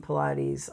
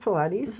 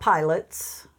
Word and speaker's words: Pilates: Yeah, Joseph Pilates. Mm-hmm. He was a real guy. – A real person. – So it's not Pilates, which is some people pronounce it when it's written.